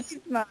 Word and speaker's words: Yzma: It's been a while since I've seen Yzma.